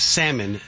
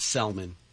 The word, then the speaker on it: salmon: Again, it's salmon, not Selman.